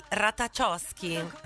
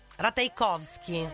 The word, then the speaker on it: Ratajkowski: La celebrity crush di Harry Styles era proprio Emily Ratajkowski. Ratajkowski, scusate, l'ha detto anche lei come si pronuncia.